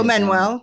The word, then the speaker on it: Manuel: But as as actors, actresses, Neve Campbell, Manu, how, how do you say? – Oh, Manuel, it? Manuel, Manuel, Manuel. Yeah.